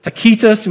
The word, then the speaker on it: Tacitus: This is Tacitus or Tacitus when you pronounce it in the Latin.